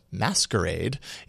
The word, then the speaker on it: MSQRD: An app called MSQRD, but meant to be pronounced as Masquerade, is here to creep you out.